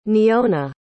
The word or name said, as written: neona